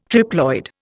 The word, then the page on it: triploid: Indian